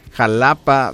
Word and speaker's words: Xalapa: Y no, qué padre, gracias a Cintia Triple M y el Dr. López que me ayudan, porque así es, se escribe con X-A-L-A-P-A, pero yo no sabía que se pronunciaba con como si fuera la G. Jalapa,